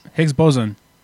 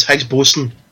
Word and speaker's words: Higgs boson: How did you say that? Higgs what? – Higgs boson. – No, it's Higgs boson.